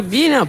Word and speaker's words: Bina: Questa era Bina con Dopamine, Bina o Baina, chissà come si pronuncia, non ho trovato nessuna intervista né lei che... – Cioè, poi Bina, punto. – Bina, punto, esatto. È così che si chiama.